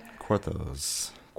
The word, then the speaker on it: quatorze: Quatorze.